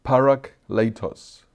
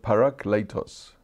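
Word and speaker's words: parakletos (παράκλητος): And you see it's the word pronounced parakletos. That's how you actually pronounce it.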